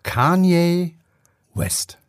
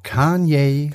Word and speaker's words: Kanye: Kenye, Kenny, Kane, Kanye, Kenye. Jetzt sag doch mal. – Er Wie heißt ist es richtig? Es wird wirklich ausgesprochen Kanye West. Kanye. Kanye. Kanye.